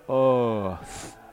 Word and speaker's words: Earth: それから And peace on earth、 Earth は Earth ではなく And peace on earth、うですね、う。どうかこの男性最後に Earth は TH をしっかり発音していただくようにお願いします。